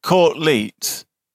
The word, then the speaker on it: Court Leet: And spelt courtly, or is it pronounced courtly but actually spelt courtleet? – It's court, C-O-U-R-T space L-E-E-T. – Oh, not C-O-R space T-L-E-A-T. – Not E-A-T, it's E-E-T.